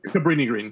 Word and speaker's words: Cabrini Green: But for me, it's like just seeing them in, just them talking about Capri Green. Wait, did I just say that right? – Cabrini Green. – Green, yeah, thank you.